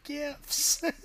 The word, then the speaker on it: GIF: Stop it with the GIFs. – Okay, so apparently the creator of this file format said that it's pronounced GIF.